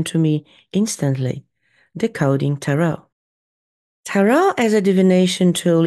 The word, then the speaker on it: tarot: I say tarot, and it really doesn't matter how you pronounce it, just so long as you don't rhyme it with carrot.